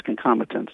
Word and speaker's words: concomitance: So is that concomitance? Is that saying it properly? – Yes, concomitance.